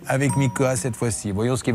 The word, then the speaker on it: Mika: Mais il faut faire attention, hein, parce que si vous dites Viana et Mickey, ils pourraient le prendre très mal. Donc il faut bien prononcer.